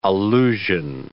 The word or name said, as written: allusion